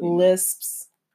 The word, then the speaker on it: lisp: Lips. It's a really hard word to say, though. – It's really lisps.